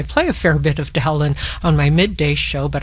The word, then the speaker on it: Dowland: But most consensus now says that it's a short O, because there's a composer by the name of Cowper who's contemporary, and so Cowper, Dowland, something like that.